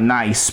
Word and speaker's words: Nice: Looks like nice because it's spelled like nice, but it's Nice, France, in December.